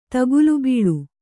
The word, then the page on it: ತಗುಲುಬೀಳು: tagulu bīḷu